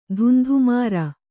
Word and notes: ಧುಂಧುಮಾರ: dhundhumāra

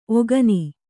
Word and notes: ಒಕ್ಕಣ್ಣ: okkaṇṇa

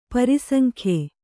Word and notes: ಪ್ರಾಸಂಗ್ಯ: prāsaŋgya

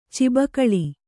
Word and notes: ಚೌಬಾಗಿಲು: caubāgilu

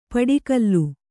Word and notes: ಪಡಿಕಲ್ಲು: paḍikallu

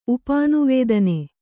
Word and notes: ಉಪಾನುವೇದನೆ: upānuvēdane

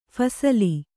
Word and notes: ಫಸಲು: Kannada

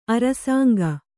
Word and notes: ಅರಸಂಕ: Kannada

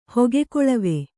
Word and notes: ಹೊಗೆಕೊಳವಿ: hoge koḷavi

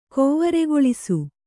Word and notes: ಕೌವರೆಗೊಳಿಸು: kauvaregoḷisu

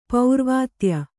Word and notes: ಪರಾವಿದ್ಯೆ: parā vidye

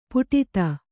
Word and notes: ಪುಟಿತ: Kannada

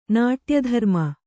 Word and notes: ನಾಟ್ಯಧರ್ಮ: Kannada

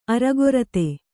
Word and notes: ಅರಗೊರತೆ: aragorate

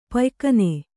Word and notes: ಪಯ್ಕನೆ: paykane

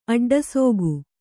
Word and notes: ಅಡ್ಡಸೋಗು: aḍḍasōgu

